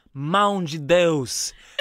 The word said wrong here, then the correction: mãe de Deus: Did I say it wrong? – I think it's supposed to say, mãe de Deus.